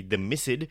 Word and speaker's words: The Missed: In very brief, I'll tell you, The Mist, M-I-S-S-E-D, not M-I-S-T, The Mist, I suppose a better way of pronouncing that would be The Missed, is a audio drama.